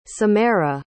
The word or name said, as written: Symera